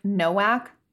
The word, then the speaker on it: Nowak: You know the spelling of Novak is N-O-W-A-K? – N-O-W-A-K. – Oh, okay. N-O-W-A-K. Like Novak? – Yeah. – They pronounce it Novak.